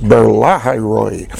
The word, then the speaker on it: Beer Lahai-roi: Behold, it is between Kadesh and Bered, and Beer in the accents on the second syllable there. It'd be easier if it was on the third, but it's Beer Lahai-roi.